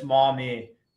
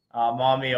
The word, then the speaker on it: Maumee: Yeah, locals have a tough time with it. It's Maumee. Maumee, Ohio is the pronunciation.